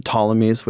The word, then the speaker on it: Ptolemies: And most famously, it's the Alexandrian Empire with the Ptolemies, with the silent P for some reason.